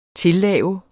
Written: tillave

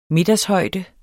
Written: middagshøjde

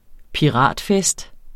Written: piratfest

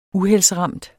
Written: uheldsramt

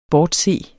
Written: bortse

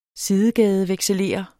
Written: sidegadevekselerer

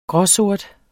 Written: gråsort

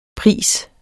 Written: pris

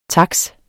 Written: taks

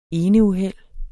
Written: eneuheld

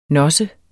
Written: nosse

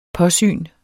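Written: PÅSYNET

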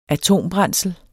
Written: atombrændsel

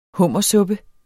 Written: hummersuppe

